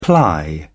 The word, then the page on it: ply: British English